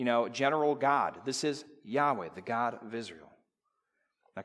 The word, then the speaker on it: Yahweh: Specifically, it's the four letters, YHWH, and you pronounce it Yahweh.